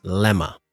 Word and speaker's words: Lemma: Wonderful person that I got to meet, Tony Lemma.